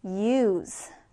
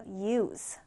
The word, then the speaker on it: use: 자, 다음 단어는 일단 동사랑 명사가 발음이 달라요. 자, 먼저 동사의 발음부터 볼게요. 동사는 use. 자, 다시 따라해 볼까요? use. use. 자, 지금 보면 끝에가 z 발음으로 끝나죠. use. 사용하다, 쓰다란 뜻이에요. use. 사용하다, 쓰다. 자, 그럼 명사는 어떻게 발음할까? s 발음으로 끝납니다. 자, 따라해 볼게요. use. use. 자, 차이가 느껴지시죠? 자, 다시 동사는 use. 동사는 use, 명사는 use. 그쵸? Z와 S 발음 여러분 잘 구분해서 연습해 주셔야 됩니다.